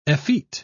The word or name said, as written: effete